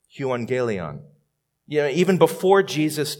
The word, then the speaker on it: euangelion: Well, it's pronounced euangelion, and I think it's kind of cool to see these Greek words.